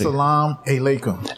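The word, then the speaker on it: As-salamu alaikum: You got to teach me how to say that. – As-salam alaikum. – And that means peace be with you.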